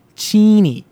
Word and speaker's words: Cheney: The town where the actual campus is located is so spelled Cheney, which how would you pronounce that? – Cheney? – Yeah. It's pronounced Cheney.